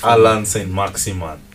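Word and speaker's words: Allan Saint-Maximin: I don't know what's his Allan Saint Maximin.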